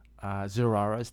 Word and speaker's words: Zarathustra: How do you say that name? – Zarara's Tears. – There you go.